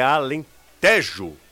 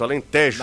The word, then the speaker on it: Alentejo: E aí a pronúncia correta da região lá de Portugal é Alentejo. Tejo. – Tem, tem um. Um acento. Um acento, Alentejo.